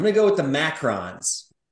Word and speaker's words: macaron: But I'm going to take the one spelled M-A-C-A-R-O-N, not the O-O-N. It's pronounced macarons.